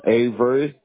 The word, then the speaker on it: Avery: All right, so for account purposes, is your first name J-O-E-L? – Yes. – Last name? – Avery. – A-V-E-R-Y? – Yep.